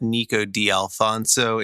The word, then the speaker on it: Nico D'Alfonso: Yeah, you can follow me on Twitter and Instagram at Nico D'Alfonso.